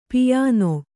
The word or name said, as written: ಪಯಣ